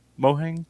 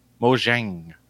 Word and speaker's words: Mojang: Mohang? – Mojang. – How do you pronounce it? Mojang.